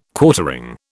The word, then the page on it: Quartering: British English